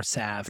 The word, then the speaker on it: salve: Yeah, it really is amazing how much of a salve or salve, depending on whether or not you pronounce the L, just being able to be connected to nature and being able to get your hands in dirt and feel real life growing.